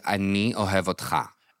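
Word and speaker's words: Ani ohev otcha (אני אוהב אותך): So in Hebrew, it's say, Ani ohev otcha.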